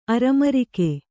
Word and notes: ಅರಮರಿಕೆ: aramarike

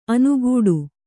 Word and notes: ಅನುಗೂಡು: kannada

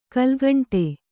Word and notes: ಕಲುಗುಂಡು: Kannada

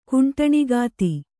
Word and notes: ಕುಂಟಣಗಿತ್ತಿ: kannada

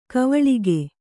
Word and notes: ಕವಳಿಗೆ: kavaḷige; Kannada

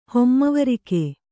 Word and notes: ಹೊಮ್ಮವರಿಕೆ: Kannada

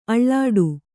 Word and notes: ಆಲೀಢ: ālīḍha

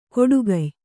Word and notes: ಕೊಡುಗಯ್: koḍugay

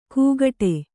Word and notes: ಕೂಗಟೆ: kūgaṭe

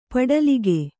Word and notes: ಪಡಲಿಕೆ: paḍalike

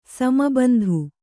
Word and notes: ಸಮಬಂಧು: sama bandhu

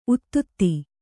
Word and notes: ಉತ್ತುತೆ: uttute

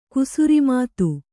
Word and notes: ಕುಸುರಿಮಾತು: kannada

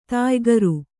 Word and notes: ದಯಾಕರ: dayākara

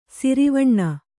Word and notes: ಶರವಣ: śaravaṇa

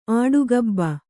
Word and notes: ಆಡುಗಬ್ಬ: āḍugabba

